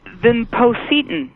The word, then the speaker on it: vinpocetine: And vinpocetine.